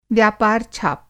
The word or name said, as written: व्यापार-छाप